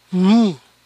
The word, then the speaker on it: knee: Ganar, earn, earn, E-A-R suena er, earn, learn, earth, early. Jefe, chief, chief, Final, if, chief, brief, thief. Rodilla, knee, knee. La K no se pronuncia.